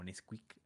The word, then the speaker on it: NSQK: Eh, se llama, bueno, se escribe con, son siglas, es NSQK, e pero pues, se pronuncia como Nesquik.